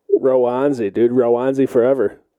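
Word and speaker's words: Roansy: Well, I think we used to say Ronzi, which is super white of us. – Yeah. – But I'm pretty positive it's Rowanzi.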